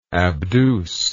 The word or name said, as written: abduce